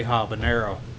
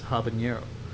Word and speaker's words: habanero: So, pumpkin ale featuring cinnamon and they put a little squiggly on the end, so it's habanero. Instead of I've always pronounced it. I'm from Texas. I say habanero, but it's habanero.